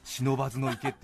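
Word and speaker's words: 不忍池: 読むときはちゃんと書き下し的に忍ばずの池って読まなきゃいけないみたいで忍ばずの池ってねあの書くときはみんな漢字 3 文字で「ふ」忍池って書いて忍ばずの池って読むんです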